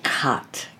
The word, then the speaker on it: Kaat: That's the energy of Kaat, K-A-A-T, Kaat, it's pronounced.